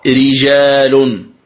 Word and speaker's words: رجال: قال المؤلف وفقه الله: مثال ذلك قوله تعالى رجال، فيه رجال يحبون، رجال كلمة رجال الراء مرققة وهي وقعت في أول الكلمة، الراء مرققة لأنها مكسورة، إذن تقرأ رجال، ري هكذا تنطق الراء المكسورة، ري رجال.